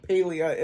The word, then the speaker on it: Palia: I have asked so many people. Palia is the like consensus.